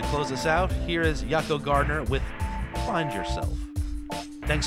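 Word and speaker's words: Jaco: I'm going to leave you here with something from Yako Gardner, which is, I'm pretty sure you say his name, not Jocko, I believe it's Yako.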